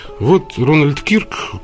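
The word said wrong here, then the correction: Kirk: которого, вы извините, Кёрк, он правильно произносится, который, я привык его Кирк произносить,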